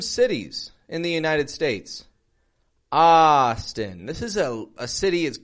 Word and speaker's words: Austin: And notice again the contrast in the syllable stress. Austin, strong, weak.